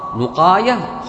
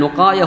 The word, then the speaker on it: نقاية: النقاية اسم لكتاب، اسم لكتاب اسمه النقاية على وزن الخلاصة، نقاية خلاصة، اجعل نقاية هنا وخلاصة هنا، كما تنطق خلاصة انطق نقاية.